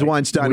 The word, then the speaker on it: Weinstein: We never get his name right. – It's Weinstein. We didn't know that now.